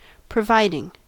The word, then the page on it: providing: American English